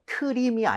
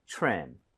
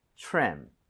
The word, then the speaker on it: trim: 수가 있는데 이거 트림이 아니죠. – 예. – right? TRE 붙어 있어요. TRE 붙어 있고 그다음에 모음으로 연결이 되면 크리스마스 트리처럼 치읓 비슷해집니다. – 그쵸. – 그래서 입 모양을 모으고 발음해 주세요. 가보겠습니다.